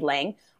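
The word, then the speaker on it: Leng: George says he doesn't really care how we pronounce things, but I say Lung because that would be the Chinese way to pronounce it, but I know it's inspired by H.P. Lovecraft and should be Lang.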